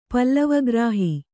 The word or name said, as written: ಪಲ್ಲವಗ್ರಾಹಿ